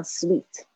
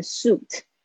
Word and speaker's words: suite: OK，有点听起来有点像 sweet 那个发音，发音有点像那个，所以是不是稍微注意一下这个 ui 发 we 的声音，所以不要念成 suit，